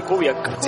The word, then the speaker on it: Kubiak: Yo siempre le había dicho Kubiak, y platicando con él me dice que los estadounidenses tienden a decirle mucho Kubiak, que su apellido es croata y que la pronunciación correcta es Kubiak.